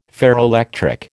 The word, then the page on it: ferroelectric: American English